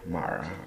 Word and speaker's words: Mara: I don't know why I did my R like that, but it's spelled M-A-R-A, Mara, or Mara.